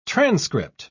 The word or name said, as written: transcript